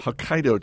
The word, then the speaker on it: Hokkaido: Pepper bacon, rich cheese, Hokkaido cheese. Am I pronouncing that correctly, Bill Mann? – You got it. – Hokkaido cheese.